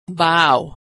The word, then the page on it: vow: aw